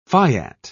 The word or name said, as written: fiat